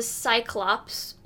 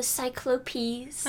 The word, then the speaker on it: Cyclopes: The Cyclops, or if you want to be pretentious, you can pronounce it as the Cyclopes.